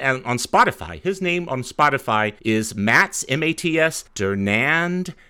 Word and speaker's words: Dernand: The accent's on the A, so I would say Dernand, just like you did.